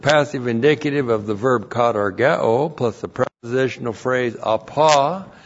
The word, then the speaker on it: katargeo: We have the verb katargeo. K-A-T-A-R-G-E, long O. Katargeo.